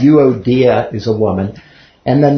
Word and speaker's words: Euodia: So this is euodia, okay? The emphasis is on the third syllable. So euodia.